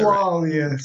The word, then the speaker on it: Coaglia: Just to get that Coaglia, silent G.